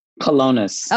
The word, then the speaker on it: Colonus: The Gospel at Colonia. How do you pronounce that? – Colonus. – Okay, I was close. The Gospel at Colonus.